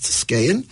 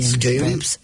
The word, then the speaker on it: Skagen: If you look on your map, it's spelled in English, of course, in our, well, Latin letter, S-K-E-G-E-N, but pronounced Skagen. – I think A-G-E-N. – Skagen. Did I say E? No, I meant A. S-K-A-G-E.